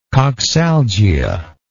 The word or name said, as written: coxalgia